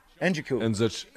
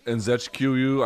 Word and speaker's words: Nzekwu: Lightweight Da Un Jung opened this card with some nasty elbows against Kennedy Nzekwu. Njoku. – Nzekwu.